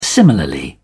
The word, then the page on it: similarly: British English